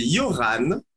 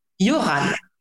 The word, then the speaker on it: Yoran: Alors, c'est Yoran. Yoran. D'accord. Yoran. cest dire qu'il y a le R et le H des muets.